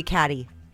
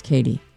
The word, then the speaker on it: Cady: C-A-D-Y. – Katie Heron. Oh, that's how you say it? C-A-D-Y? – Yeah, well, that's how it's spelled. And they're like, I'm going to call you Caddy. – And she's all, no, it's Katie.